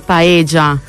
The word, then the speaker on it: paella: No, ragazzi, la doppia L in spagnolo si legge G, si dice paella.